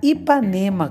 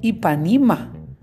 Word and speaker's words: Ipanema: era Ipanema com E. Ipanima era frescura de gringo.